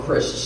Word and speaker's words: Crist: My last name is spelled Christ, C-R-I-S-T.